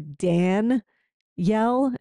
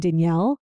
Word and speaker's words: Danielle: I guess they want me to say it with more of a Dan yell and I was saying Danielle.